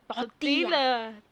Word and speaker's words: tortilla: Tortilla. – It's tortilla. – Tortilla. – You don't pronounce the L, bro. Double L.